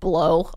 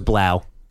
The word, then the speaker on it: Blough: The one backup quarterback I remember from Thanksgiving is the Lions rolled out a guy named David Blow. And I was like, man, it's spelled B-L-O-U-G-H, but I believe it's pronounced Blow. – No, it's Blau.